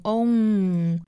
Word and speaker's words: only: 美式发法呢跟刚才我们讲到的这个英式发法需要注意两个词，一个是 only 要发成 only，那这个元音呢我们需要注意它就像我们说那个字母 o 的发音，然后再去加一个前鼻音 n， own 和 n 连在一起呢变成 only。